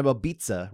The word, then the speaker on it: Babitza: Diana Babitza, rhymes with pizza, got the win over Maria Oliveira, rhymes with era.